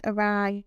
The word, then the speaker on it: awry: It is no surprise when violence strikes, tempers change, and the group I still affixed the screen starts to rapidly go awry, awry, awry. Is how you say it? That's how you say it. That's how. That's awry. Awry.